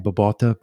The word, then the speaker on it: Babauta: Babauta, B-A-B-A-U-T-A.